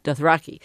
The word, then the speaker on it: Dothraki: Now, you've created many languages, but we'll just stick with, is it Dothraki? Is that how you say it? – Dothraki? – Dothraki. Okay.